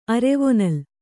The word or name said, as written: ಅರೆವೊನಲ್